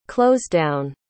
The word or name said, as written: closedown